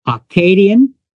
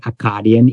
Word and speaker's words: Akkadian: So the two basic ones were Akkadian. Some say Akkadian. Either way is correct.